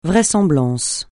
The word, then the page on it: vraisemblance: francais